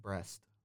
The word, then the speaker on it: Brest: And PSG have Brest. Not as in, like... – 16th. The B-R-E-S-T.